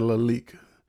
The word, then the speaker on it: Lalique: It's like Lilique or something like that. – How you spelled it? – LILAIQUE. – Lalique. – Yeah, Lalique. Lalique, that house.